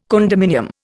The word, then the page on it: condominium: Indian